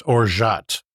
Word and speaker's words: orgeat: Yeah, it's pronounced orgeat.